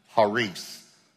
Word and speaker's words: charis: The Greek word for grace is actually pronounced charis, but you've seen it spelled charis.